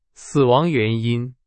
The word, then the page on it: 死: Chinese